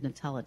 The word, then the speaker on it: Nutella: How do you pronounce that? Nutella? – That's Nutella. What I've heard everybody do it. Yes, Nutella.